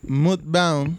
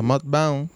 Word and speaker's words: Mudbound: bueno, mis sugerencias son eh, Moodbound, ¿verdad? Que lo dije. MUD de fango, Mood. Pero es, eh, yo creo que se, se pronuncia Mudbound,